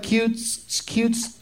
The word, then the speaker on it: Cute Streak: Am I pronouncing it right? Cute Streak? – Yep. – Okay. Because it kind of looks like Cute Streak. I want to say steak for some reason when I see it.